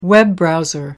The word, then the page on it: web browser: American English